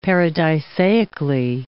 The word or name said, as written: paradisaically